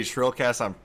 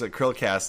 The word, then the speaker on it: Chrillcast: Yeah, it's pronounced Krillcast, like the krill, like the whales eat.